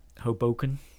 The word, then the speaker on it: Hoboken: And they actually took me to Hoboken.